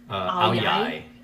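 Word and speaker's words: áo dài: The way you spell Aoyai is A-O space... D-A-I. – Yeah. – It's a Vietnamese. – You do not pronounce the D like a D. You use a ya sound for the D.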